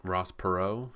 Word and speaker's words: Perot: Did I say it right, Perot? I thought I did. – Ross Perot? Yeah.